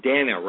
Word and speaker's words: Dahna: Dahna, right? – Dahna, yes. Thank you. – Dahna, okay.